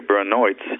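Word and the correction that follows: Beinart: I think that's Baynard. – Oh, is that how it's pronounced?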